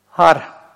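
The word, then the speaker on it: Хар (הר): Хар – гора. Хар – да, видите, начинается с хе, которое как легкое придыхание произносится. Хар.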